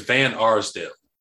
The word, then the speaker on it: Van Arsdale: I do like Lauren Varnsdale. Did I pronounce it right? – I think it's Van Arsdale.